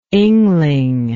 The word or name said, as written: Yngling